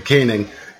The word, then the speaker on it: Koenig: So they need Ethan Happ and Kronig, what is this? How do you pronounce his last name? – Koenig, to be the dudes.